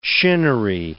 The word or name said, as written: shinnery